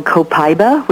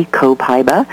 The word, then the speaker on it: Copaiba: And, but you mentioned the CBD oil, and now doTERRA has brought out an essential oil from a plant, and it's called Copaiba, which is C-O-P-A-I-B-A, Copaiba, sometimes pronounced Copaiba, but we'll just go there.